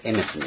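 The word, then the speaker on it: エメ ス (אמת): 発音ではこれエメスになるわけですけども、別のみたいなやつですね。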